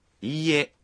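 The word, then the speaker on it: Iie (いいえ): Khi nói iie, các bạn phát âm chữ i dài ra một nhịp.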